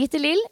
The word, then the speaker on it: Gitte-Lill: Altså, det ble et lite opphold eh, mellom den introen eh, vår, Pia, og eh, nå videre praten med deg, Gitte eller Gitte Lill. Hva foretrekker du? – Altså, Jeg bruker egentlig bare Gitte, men uh, i USA når jeg jobbet der i ni år, så var det ingen som kunne uttale det. Og i Frankrike så sa de What how you pronounce your name? Shit! Så tenkte jeg ja, jeg får bruke Gittelil på jobb, da.